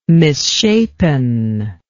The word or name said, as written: misshapen